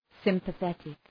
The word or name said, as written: sympathetic